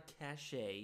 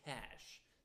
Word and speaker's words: cache: No, it's not a cache, it is a cache. That's how you say it.